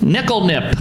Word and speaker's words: Nikl Nip: Nickel nip. Is that right? – Spell it. – N-I-K-L-N-I-P.